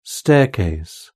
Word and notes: staircase: British English

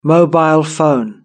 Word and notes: mobile phone: British English

